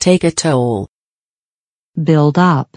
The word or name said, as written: take a toll